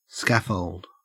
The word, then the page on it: scaffold: Australian English